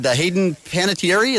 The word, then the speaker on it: Hayden Panettiere: He would be, They had Hayden Panettiere. Is that how you say your name? – Mm-hmm. Yes.